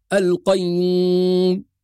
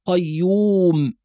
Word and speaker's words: القيوم: فالذي يعاني مشكلة النطق من الأنف أو الخيشوم نعطيه علاج بسيط جدا، نقول له عليك أن تضم الشفتين ضم كامل فتقول قيوم ولا تقول قيوم،